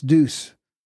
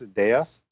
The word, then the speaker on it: Deus: Either way, but okay, the proper way would be Rex Deus.